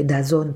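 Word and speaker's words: DAZN: Un esempio è Dazon per esempio che si scrive con eh, la D-A ZN senza eh, vocale tra la Z e la N e alla fine a furia di ri- ripetere la pronuncia da Zon il pubblico l'ha, l'ha memorizzata.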